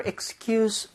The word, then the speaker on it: excuse: 그래서 뒤에 부분 S를 C에 가깝게 좀 발음해 주셨으면 좋겠어요. 그래서 한번 해볼게요. Excuse. Excuse. – Excuse. Excuse. – 원래 excuse me 여기서 excuse 있잖아요. 네. 걔는 Z로 발음을 하는 거거든요.